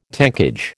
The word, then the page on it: tankage: American English